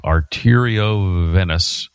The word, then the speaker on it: arteriovenous: But the condition is that I had is a rare spinal condition called spinal dural arteriovenosis. No, I'm saying it wrong. Arteriovenous fistula.